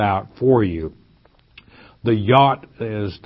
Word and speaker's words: yod: If you have your Bible, you'll have that J-O-D, which is pronounced yod in the Hebrew.